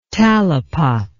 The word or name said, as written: talipot